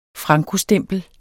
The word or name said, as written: frankostempel